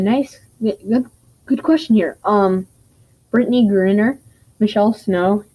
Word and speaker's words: Griner: Griner. Yeah, it's G-R-I-N-E-R.